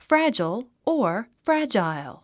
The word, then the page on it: fragile: frǽdʒəl; フラジャル